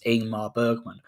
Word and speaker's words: Ingmar Bergman: I'm believing it's pronounced Ingmar Bergman.